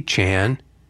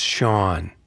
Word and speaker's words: Chan: So I walked into the control room and I said, "Hey Chan." And she said, "It's Sean." And she turned around and walked away. And I felt like a complete idiot. Of course, her name is spelled C-H-A-N. But you'd think I would have figured out how to pronounce it right, but I didn't.